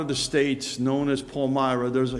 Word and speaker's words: Palmyra: You got to pronounce it differently in Pennsylvania. In New York, it's Palmyra. Down here in Lancaster County, it's Palmar.